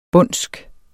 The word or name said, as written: bondsk